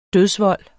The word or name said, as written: dødsvold